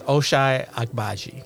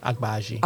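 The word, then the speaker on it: Ochai Agbaji: I didn't figure out how to pronounce it. It's Oshai Agbaji. – Ah, there we go. – Say his last name again. – Agbaji.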